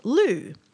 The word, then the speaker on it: Lugh: And in some pagan traditions, Lammas is also the day of honouring the Celtic god Lu, which is spelled L-U-G-H.